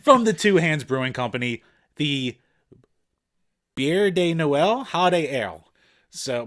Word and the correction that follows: Bière de Noël: So the Bière de Noël.